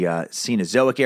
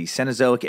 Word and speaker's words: Cenozoic: Megalodon is a prehistoric shark that lived approximately 23 to 2.6 million years ago during the Cenozoic era, sometimes pronounced as the Cenozoic era.